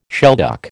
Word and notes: shelduck: American English

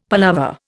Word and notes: palaver: Indian

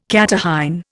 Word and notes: catarrhine: Indian

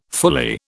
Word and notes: fully: British English

